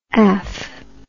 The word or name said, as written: af